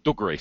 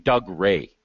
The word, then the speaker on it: Dougray: It's not even Doug Ray. He's not Doug Scott. No, Dougray. That's how it's pronounced.